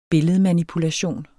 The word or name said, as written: billedmanipulation